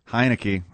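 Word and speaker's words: Heinicke: Henneke, am I saying that right? – Heineke has all of his weapons back.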